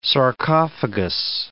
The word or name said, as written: sarcophagus